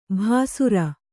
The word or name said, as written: ಭಾಸುರ